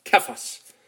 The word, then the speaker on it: Cephas: In Aramaic, it would have been pronounced Kephas, meaning a rock or a big chunk that had been broken off a massive ledge.